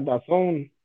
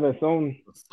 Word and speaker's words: DAZN: Eh, Jürgen Max, me parece que Dazun se pronuncia Dazón como si fuera The Zone. He escuchado que lo pronuncien, que lo pronuncian así en el Italian GP de motocicletas. Sí, creo que ah, sí es The Zone.